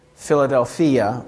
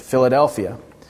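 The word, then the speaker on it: philadelphia (φιλαδελφία): As you may or may not know, the Greek word for brotherly love is philadelphia, or as we might pronounce it, Philadelphia.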